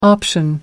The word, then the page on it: option: American English